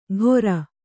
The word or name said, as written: ಘೊರ